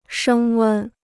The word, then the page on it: 升温: shēng wēn